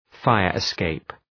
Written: fire-escape

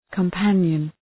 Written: companion

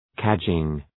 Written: cadging

